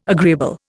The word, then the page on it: agreeable: Indian